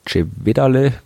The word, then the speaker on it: Cevedale: äh, die hat man äh, jetzt am Monte Cevedale, Cevedale, weiß gar nicht, wo der Berg ist, auf irgendeinem Gletscher auf jeden Fall, äh, in Italien, dann ist es, muss, spricht man es italienisch aus.